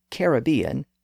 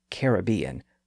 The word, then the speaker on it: Caribbean: Also, since I'm going to be talking about the Caribbean, let me acknowledge that there are two common pronunciations of that word, especially in American English. We have Caribbean and Caribbean. I will admit that my pronunciation often fluctuates between the two. But for purposes of this podcast, I'm going to use Caribbean, which is the older and more traditional pronunciation within English.